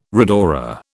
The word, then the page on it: rhodora: British English